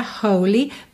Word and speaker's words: holy: Normalmente a sílaba tônica seria a primeira, holy, mas a gente vai ter um alongamento dessa segunda sílaba, então holy.